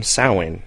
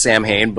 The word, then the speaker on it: Samhain: Is that how you pronounce it? – That is correct. Okay, that would be the correct Gaelic way to say it. Here in the exactly. States, everyone says Samhain, but yes.